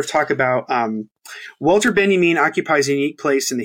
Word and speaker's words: Benjamin: Um, I always say his name Benjamin because that's how it's supposed to be said because he's German or whatever.